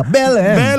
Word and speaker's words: Belém: Belém.